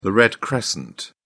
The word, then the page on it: red crescent: British English